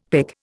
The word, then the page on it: pic: Indian